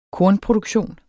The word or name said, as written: kornproduktion